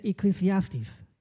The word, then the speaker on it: Ecclesiastes: And to do that, I also want to bring Professor Solomon here, that he also wrote another great book called the I can never pronounce this word, the Ecclesiastes. Perfect.